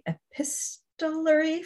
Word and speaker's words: epistolary: So you're writing in, am I going to say this right, the epistolary form, the letter writing form? – Yes.